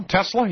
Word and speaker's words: Tesla: Did I say that right this time, John? Tesla? Yeah. Tesla has a handmade electric sports car for $100,000.